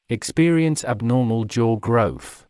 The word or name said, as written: experience abnormal jaw growth